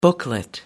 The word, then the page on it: booklet: American English